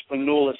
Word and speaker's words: Spanoulis: Spanoulis. Am I saying that right? – Yeah, well, yeah, I was really high on Spanoulis.